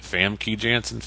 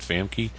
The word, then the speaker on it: Famke Janssen: Is it Famke Janssen? Famke? Right. But